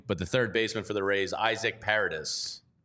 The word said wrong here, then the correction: Paredes: Paredes. – Paredes. – Paredes. – Paredes. A little stink on the D. More Espanol.